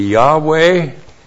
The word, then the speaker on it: Yahweh: If it was actually pronounced in Hebrew, it would be Yahweh.